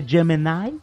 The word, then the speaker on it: Gemini: Desculpe, gente, a pronúncia correta é Gemini? – Gemini, tá perfeito.